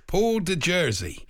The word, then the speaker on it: de Jersey: The Honourable Paul de Jersey.